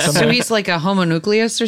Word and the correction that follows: homunculus: How do you say that homunculus? – Word? – Homunculus? – Homunculus. There it is.